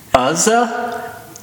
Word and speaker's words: Uzzah: So Uzzah. Uzzah? I've heard it pronounced both ways. – Uzzah.